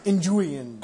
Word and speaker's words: Nguyen: I'm assuming you pronounce this Nguyen? It's N-G-U-Y-E-N? – No, it's Nguyen.